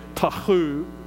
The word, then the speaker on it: tohu: That word formless is the Hebrew word tohu, T-O-H-U. Actually, if we were to pronounce it like the Hebrews, it would probably be Tahu, because you almost had to clear your throat to say the H there.